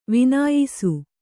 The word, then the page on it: ವಿನ್ಯಾಸ: vinyāsa